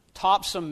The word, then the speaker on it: Topsham: As Pastor Doug said, I'm from Topsham, Maine. It's spelled Topsham with an S-H, but Mainers pronounce it simply as S.